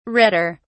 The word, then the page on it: redder: British English